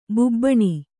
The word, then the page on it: ಬಾಬೂನ: bābūna